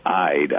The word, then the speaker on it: Ide: For example, our April author, Joe Ide, it's spelled like Ide, I-D-E, but it's pronounced Ide.